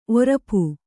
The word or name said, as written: ಒರಪು